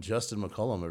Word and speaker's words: Justin McCollum: This guy, please welcome Justin McCollum, everybody.